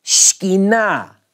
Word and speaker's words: Shekinah: We say it in the South with three syllables. We do this to a lot of two-syllable words. In Hebrew, it's really Shekinah. Shekinah. S-H-K-N-E-E is the first syllable. It's really not two syllables.